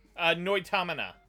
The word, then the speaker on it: Noitamina: How do you pronounce it? – Noitamina. – Noitanima. Thank you. It's the word anime spelled backwards.